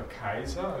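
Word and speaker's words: Caesar: Das C ist ja im Lateinischen als K ausgesprochen worden eigentlich, daher kommt auch der Kaiser, also Cäsar lateinisch ausgesprochen Kaiser, daher kommt das deutsche Kaiser.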